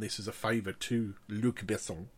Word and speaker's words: Luc Besson: No, you said it wrong. I'm sorry. – Luc Besson. – That's better. – Luc Besson.